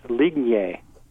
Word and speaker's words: Lignje: It's Lignje which if you speak Serbo-Croatian, you understand. But if you don't, it's L-I-G-N-J-E at stanford.edu. – Excellent. So that's L-I-G-N-J-E at stanford.edu.